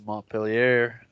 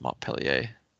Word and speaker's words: Montpellier: It's French. You don't say the R. – All right, this is in America where we pronounce the words correctly. – I don't think that's how it's pronounced, really. – It's Montpellier.